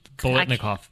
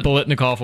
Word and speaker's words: Biletnikoff: Did win the Billing. – Biletnikoff. – You want to pronounce it? Biletnikoff. – How are you going to pronounce it? – Billinkoff. Belichick. – Won the Fred Biletnikoff Award.